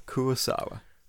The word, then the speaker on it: Kurosawa: So Kurosaga. That's who we're dealing with today. Um, apps, Kurosawa. Kurosawa. Did I say it wrong? I'm going to say so many names wrong. – Akira Kurosawa.